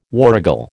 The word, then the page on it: warrigal: American English